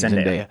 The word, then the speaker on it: Zendaya: And Zendaya is sort of like, wait, weren't we saying it wrong? Isn't it Zendaya? I'm pretty sure it's Zendaya. – It's Zendaya, and that we've been saying it wrong for like 2 years.